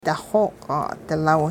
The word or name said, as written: daxooq'-'adilaw